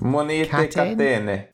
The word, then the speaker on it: Monete Catene: Is it Kateni? Is that how you pronounce it? – Monte Kateni.